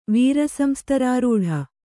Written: ವೀರಸಂಸ್ತರಾರೂಢ

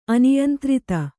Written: ಅನಿಯಂತ್ರಿತ

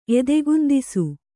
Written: ಎದೆಗುಂದಿಸು

